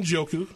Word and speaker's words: Njoku: You know, and for you fantasy football players, I can't say the tight end's name, but Njoku or something like that. How do you say his name? – Njoku.